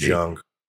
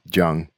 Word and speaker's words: Jung: I believe it's pronounced Jung. – Yeah, Jung. – I think it's Jung, but, Carl Jung.